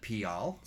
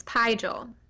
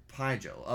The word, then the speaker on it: Pijal: I originally, when I was reading this book, I called it Pial. – So because did I. – I was like, oh, that's probably how it's pronounced, but in the audiobook, it's Pijal. – Pijal.